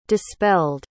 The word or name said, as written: dispelled